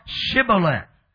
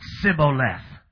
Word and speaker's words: Shibboleth: They said unto them, Say now, Shibboleth. And the Bible said, they said, Sibboleth. Which they left the H, amen, they could not pronounce the letter H.